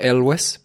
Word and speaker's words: Elwes: Sí, verdad, yo también tenía la duda, yo le pronuncio Elwes porque encontré una entrevista de él pronunciándolo así que nada, así se queda.